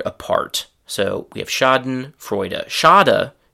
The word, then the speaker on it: Schadenfreude: So the German pronunciation is very similar, Schadenfreude, and in English it is Schadenfreude.